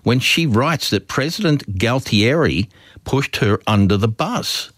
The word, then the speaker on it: Galtieri: G-A-L-T. – Oh, Galtieri, yeah.